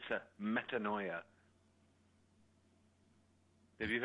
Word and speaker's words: metanoia: It rhymes with paranoia. – Yeah, I have a new word now. – I had a metanoia a couple of years ago,